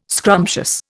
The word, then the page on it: scrumptious: Indian